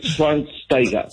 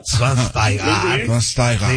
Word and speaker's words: Schweinsteiger: Después de 37 años de vida, ya sé cómo se pronuncia. Bastian Schweinsteiger. – Schweinsteiger. Sí, muy bien.